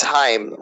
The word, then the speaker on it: Haim: No, it's Haim. Rhymes with time.